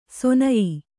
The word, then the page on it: ಸೊನಯಿ: sonayi